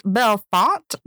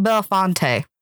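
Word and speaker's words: Bellefonte: It's pronounced Belfont, not Belfonte. And we said it all up and through that episode. So whoops. – Dang. That's the English hard because the way it's written, you would think it's Belfonte, but okay.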